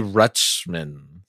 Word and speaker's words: Rutschman: Adley Rutschman. – Yeah. Yeah. That's how you say it.